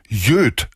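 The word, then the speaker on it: Yöt: Diese drei aus Helsinki kommend wohl nennen sich Jöt, so müsste man es etwas unbeholfen aussprechen, Y-Ö-T.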